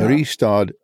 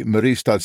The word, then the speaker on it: Mariestad: Well, if listeners want to look for that, Mariestad is, and I still am not pronouncing it right. I know it's Mariestad. Yeah. Mariestad, Sweden. And, or if I say it in Swedish, it'd be Mariestad, Sverige. Sorry.